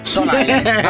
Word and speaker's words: Stone Island: Questi jeans della Stone Islands. – Stone Island. – Ma perché scrivono come si legge? – Si chiama così, Stone Island.